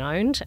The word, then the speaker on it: pwned: And actually, there's a great site that Troy Hunt runs called Have I Been Owned? And owned is spelt P-W-N-E-D because, of course, in cyber we have to have cool terms that don't sound like English.